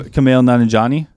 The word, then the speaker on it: Kumail Nanjiani: Kumail Nanjiani? – Him.